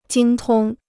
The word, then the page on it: 精通: jīng tōng